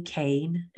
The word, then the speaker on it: Kean: They are journalists and authors, and that is Leslie Kane, and her last name is K-E-A-N. It's pronounced a little differently than you would think, but Leslie Kane and Lynn McTaggart.